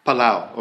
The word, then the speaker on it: Palau: Palau, okay, thank you.